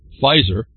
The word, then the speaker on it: Pfizer: Giant Pfizer, see the P's are silent, P-F-I-Z-E-R, the P is silent.